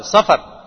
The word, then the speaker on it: صفر: اصل اس کا جو تلفظ ہے وہ ہے صاد اور فا دونوں پر زبر سفر، تو بعض لوگ اس کو صفر پڑھتے ہیں کہ بھائی سفر جو ہے وہ صفر، ایسی